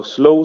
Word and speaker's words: sloe: Ja, det er, altså, vi staver det S-L-O-E, Altså slow som i slåen på engelsk. Ja. Øh, så, men når du udtaler det, så lyder det jo som en langsom død.